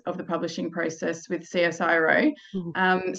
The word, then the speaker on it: CSIRO: So is it Cisco Publishing? Is that how you say it? Or CSIRO. Yeah. – Okay. Not right at all, but yeah.